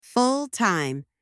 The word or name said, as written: full-time